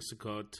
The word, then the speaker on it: Sukkot: The swastikas were painted during Sukkot.